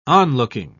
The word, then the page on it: onlooking: British English